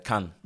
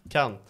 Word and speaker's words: Cannes: Eh, hur var åren i, nu måste jag uttala det här, hur uttalar det franska laget rätt? Eh, Cannes. Cannes.